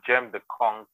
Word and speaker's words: Gemdeconq: So I think you said the name of the barrow business, your barrow business is Gemdeconq. Am I pronouncing it right? – Yeah, Gemdeconq.